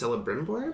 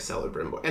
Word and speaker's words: Celebrimbor: I will say, I recognized one of the names, character names in the cast, whoever the elven smith is, Celebrimbor. – No hard, no soft C's.